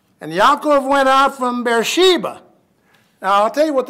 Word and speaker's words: Beersheba: B-E-E-R. I prefer to pronounce it bear instead of beer, but Beersheba. Okay.